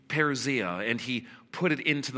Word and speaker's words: parousia: And he took this very important Greek word, pronounced parousia.